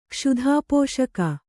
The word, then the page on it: ಕ್ಷುಧಾಪೋಷಕ: kannada